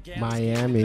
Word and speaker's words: Miami: Or Miami, how do you say? – Miami. Welcome to Miami.